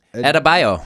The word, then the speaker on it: Adebayo: Adebayo. – Adebayo.